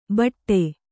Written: ಭಾಟಾ

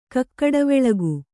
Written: ಕಕ್ಕಡವೆಳಗು

